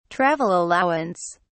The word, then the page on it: travel allowance: British English